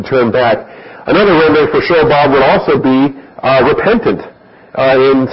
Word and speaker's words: Shobab: Under point E, we give you Shobab, S-H-O-W-B-A-B, Shobab. And that actually is a short ah sound on the bob. It's not Shobab. It's Shobab, and if you're going to give it the pronunciation, I won't go back into that.